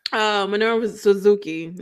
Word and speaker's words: Minoru Suzuki: Kenta versus Mino Suka. Nah, how you say it, girl? – I don't even forgot how to say it. Minoru Suzuki is what his name.